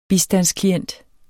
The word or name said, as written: bistandsklient